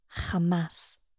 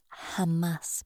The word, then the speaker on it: Hamas: Hamas. Rightly pronounced Hamas, but not to be confused with Hummus, is an acronym of Harakat al-Muqawama al-Islamiyya, Islamic Resistance Movement.